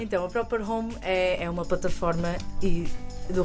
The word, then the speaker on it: Proprhome: Eles fazem parte de uma plataforma que chega ao mercado, direcionada para o ramo imobiliário, chamada Proper Home. Estou a pronunciar bem, tenho sempre medo estas coisas de estrangeirismo. Estou a dizer bem? – Perfeitamente.